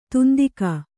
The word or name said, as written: ದಂತಿಗ